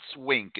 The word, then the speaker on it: Winc: I posted about them on the message board, and that's Wink. It's spelled W-I-N-C, and Wink makes it easy to discover great wine.